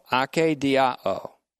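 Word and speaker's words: ake diao (ἀκηδιάω): It is ake diao, ake long E-D-I-A-O, ake diao.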